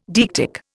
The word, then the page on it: deictic: Indian